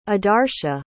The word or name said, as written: adarsha